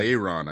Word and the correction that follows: Laenor: I think is how you pronounce his name, right? Like Yeah. – Laenor.